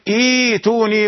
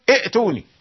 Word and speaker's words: ائتوني: إذا بدأت بالكلمة تقرأ إيتوني، أنت أتيت بهمزة وصل ونطقت همزة الوصل، فلابد أن تبدل الهمزة الثانية للتسهيل، تقول إيتوني ولا تقل ائتوني، لا تقل ائتوني، ليس في اللغة العربية إئ ولا أ ولا أ، هذه الأصوات ليست موجودة.